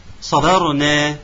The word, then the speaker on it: صبرنا: أن نفصل الصاد عن الباء فتكون صا، الصاد طبعا مفخمة، الباء مقلقلة، صادة صبا، صبرنا صبرنا، اذا كانت عفوا اذا كانت الباء يعني ساكنه فاحنا نرققها فتنضبط، فنقول صبرنا ان شاء الله ماشي.